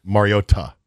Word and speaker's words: Mariota: Mariota. – We don't have to say a ta like that. It's Mariota.